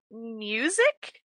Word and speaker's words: Musyc: Okay, so we are going to be reading Bring Him to His Knees by Music, is how I'm pronouncing it. M-U-S-Y-C.